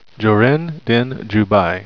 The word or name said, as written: jorin